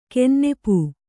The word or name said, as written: ಕೆನ್ನೆಪೂ